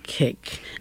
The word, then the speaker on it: koek: Cake. And I'm not sure if I'm pronouncing it correctly, K-O-E-K, cake. – You are.